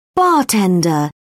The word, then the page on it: bartender: British English